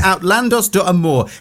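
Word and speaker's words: Outlandos d'Amour: And out now, I wasn't sure how to pronounce this, but I'm going to have a go. Outlandos de Amor.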